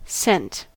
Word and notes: sent: American English

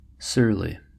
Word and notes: surly: American English

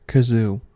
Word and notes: kazoo: American English

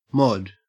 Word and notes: mod: Australian English

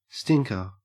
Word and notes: stinker: Australian English

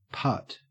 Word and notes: putt: Australian English

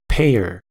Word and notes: payer: American English

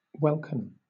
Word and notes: welkin: British English

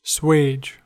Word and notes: swage: American English

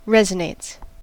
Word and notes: resonates: American English

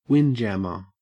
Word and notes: windjammer: Australian English

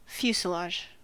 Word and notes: fuselage: American English

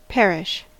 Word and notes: parish: American English

